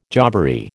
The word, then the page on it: jobbery: American English